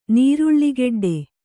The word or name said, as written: ನೀರುಳ್ಳಿಗೆಡ್ಡೆ